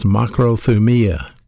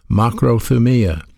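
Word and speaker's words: makrothumia (μακροθυμία): This Greek word long-suffering is pronounced makrothumia, the way it's pronounced in the Greek, makrothumia.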